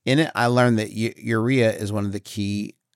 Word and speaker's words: urea: Yeah, urea.